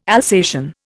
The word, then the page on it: Alsatian: Indian